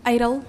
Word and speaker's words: Idil: Idil, am I saying that correctly? – Idol. – Idol? – Oh. Like American Idol.